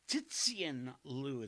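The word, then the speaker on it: Cixin Liu: Tzixin Liu, it's C-I-X-I-N-L-I-U.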